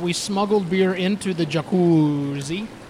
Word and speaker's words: Jacuzzi: Ah, now in the jacuzzi. That's how it's pronounced here. Jacuzzi.